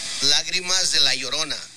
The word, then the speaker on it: Lágrimas de la Llorona: So this is how it's actually, this is how Tears of Yerona is actually pronounced. – Lágrimas de la Llorona.